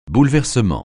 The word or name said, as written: bouleversement